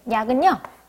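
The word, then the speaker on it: nhạc: 네, 약은요, 음악이라는 뜻이에요. 자, 음악할 때 한자의 악자가 베트남어로 발음이 될 때 약이라고 발음이 됩니다. 발음 비슷하죠? 음악.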